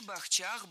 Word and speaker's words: бахчах: Действительно, бахчи, бахчах, бахчами, бахчей. Вот только ведущая немного обсчиталась. Ударение во всех формах падает не на третий, а на второй слог.